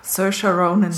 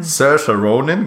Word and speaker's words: Saoirse Ronan: Saoirse Ronan. – Saoirse Ronan, genau.